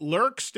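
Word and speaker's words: Lerxst: Is it Lurkst? Am I saying it right? – That's perfect.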